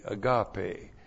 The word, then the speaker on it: agape: A-G-A-P, long E, agape.